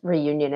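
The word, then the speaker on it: Réunion: In English, it's Reunion. It's probably fine. Okay. You don't have to do like, you don't have to like bust out the French.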